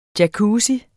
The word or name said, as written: jacuzzi